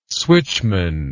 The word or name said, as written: switchman